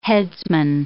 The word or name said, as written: headsman